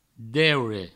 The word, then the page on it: deure: Provençal